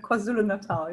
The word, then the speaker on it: KwaZulu-Natal: KwaZulu-Natal, yeah.